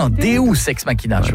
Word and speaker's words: Deus ex machina: Deux ex machina. Non, Deus ex machina. – Ah, parce que deux, j'aurais dit que c'était deux ex que t'appelles machine. – Non, ça se prononce Deus ex machina.